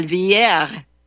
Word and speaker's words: Villere: Canal Villery. I pronounced it. I made the terrible mistake of saying Canal Villere. I mean, that's the French pronunciation.